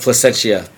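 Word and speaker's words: Plascencia: And Placenta, I can't even pronounce. I'm sorry. – FCO Placentia. – Placenta. – I didn't want to say it. Placentia.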